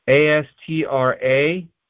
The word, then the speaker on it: Astragalus: So the first one is Astrologus Propinquus. And forgive me if I murdered that pronunciation. It is Astragalus. That's Astrologus.